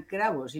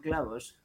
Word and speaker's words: cravos: Sí, no te preocupes, Porque además lo he dicho mal en portugués, serían cravos y no clavos. – Con R, ¿no?